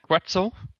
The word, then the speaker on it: Quetzal: Am I saying that right? Quetzal? Like pretzel? – Yeah, Quetzal.